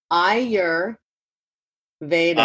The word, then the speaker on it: Ayurveda: Ayer Veda.